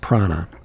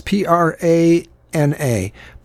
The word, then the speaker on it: prAna: Uh, a company called uh, I think it's pronounced Prana. And so it's P R A N A Prana, and the, they they capitalize the A in the middle.